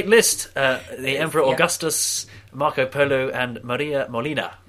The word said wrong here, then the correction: Moliner: Moliner. – There you go. How do you spell it? – M-O-L-I-N-E-R.